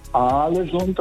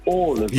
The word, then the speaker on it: Ålesund: Also das heißt nicht Aalesund, das heißt Ålesund.